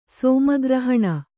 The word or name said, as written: ಸೋಮಗ್ರಹಣ